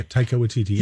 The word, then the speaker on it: Taika Waititi: Yeah, Taika Waititi.